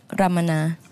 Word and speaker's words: Ramana: Ramana. – Oui. – Mais il y a une prononciation qui est un petit peu plus jolie que ça, non? – En Thaï, on dit Ramana.